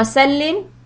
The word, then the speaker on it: salli: Just the bare minimum you can do is like Allahumma salli wa sallim ala nabiyyina Muhammad. So salli is with a sad and sallim is with a seen. So make sure that your pronunciation is okay.